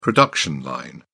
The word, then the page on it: production line: British English